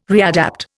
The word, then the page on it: readapt: Indian